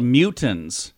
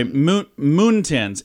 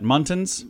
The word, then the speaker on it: muntins: those are called mutins. – Mutins. – I'm sorry, moontins. M-U-N-T-I-N-S. How would you say that? Muntins? Muntins, probably.